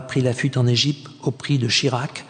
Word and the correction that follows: Shishak: C'est la fin de son de prononciation israélienne, non c'est Shishak, roi d'Égypte.